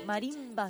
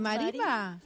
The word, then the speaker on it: marimba: Así que va a estar haciendo una meditación sonora y música de mimbra, ¿se dice? ¿Cómo se dice? Ahí le quedó. Marimba. – ¿Sabes? Ah, ¿de marimba? – Así está bien dicho, marimba.